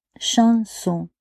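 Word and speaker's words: chanson (シャンソン): Chez nous au Japon, ça existe un mot chanson, comme un mot japonais. Ça se prononce chanson et il signifie uniquement les chansons françaises de l'époque des Biaf, Yves Montand, Barbara, etc.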